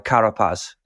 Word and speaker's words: Carapaz: So he's got me a bike that was ridden by Carapaz.